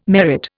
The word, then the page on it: merit: Indian